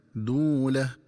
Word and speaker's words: دولة: دولة بين الأغنياء منكم. – ينبغي التنبه للنطق الصحيح لكلمة دولة، فتقرأ بضم الدال مع واو مدية هكذا: دولة.